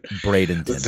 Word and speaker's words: Bradenton: WWE will hold tryouts Wednesday and Thursday, IMG Academy campus in Branton, Florida. Mm-hmm. Probably not saying that right. You would know better than I would. – Bradenton. – The tryout. What is it? – Bradenton. – Bradenton. There you go.